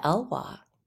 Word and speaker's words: Elwha: How do you say the name of that river? Elke River? Yeah. – The Elwha. – The Elwha. Okay.